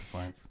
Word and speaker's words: Fiennes: It's Fiennes.